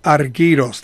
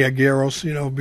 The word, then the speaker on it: Argyros: His name is Konstantinos Argyros. That's how you say it in Greek. I just say Argyros, because of... That's the English. That's the way the English, here in America, we would say it.